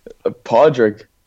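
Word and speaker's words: Pádraig: How do you say it? Come on then, Clancy, how do we say it? – Well, let the man say it himself, surely. – No, you seem to be the expert on names, so. – Podrig. – Podrig, yeah.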